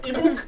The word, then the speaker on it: EMAWK: Emok, E-M-A-W-K. – Emok? – Emok. Like Chimook. Emok. Emok.